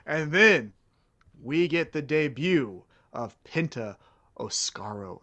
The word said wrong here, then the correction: Oscuro: Is that how you say it? – I don't I know how to no say clue. it. – Oscuro. – Uh, o S C U R O.